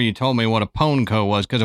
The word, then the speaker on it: Pwnco: It's P-W-N-C-O, but it is pronounced Pwnco.